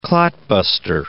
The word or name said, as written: clot-buster